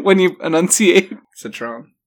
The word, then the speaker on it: Citroën: Is that how you say the car? – What? – Sure. – Citroën. – Okay. Citroën. – You don't have to pronounce every letter.